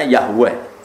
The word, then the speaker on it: Yahweh: W dengan huruf kapital, E dengan huruf kapital, H dengan bacanya Yahweh.